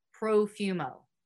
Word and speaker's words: Profumo: Side note, if you guys don't know, the Perfumo affair was a major scandal in the 20th century British politics arena. John Porfumo, I think that's how you say it. Perfumo? Porfumo. We're going to go with Profumo.